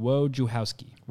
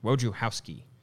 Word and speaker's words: Wojciechowski: So according to Baseball Reference, I'm seeing Wojciechowski. – Wojciechowski. – W-O-J-U-H, how, ski. Wojciechowski.